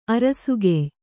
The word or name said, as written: ಅರಸುಗೆಯ್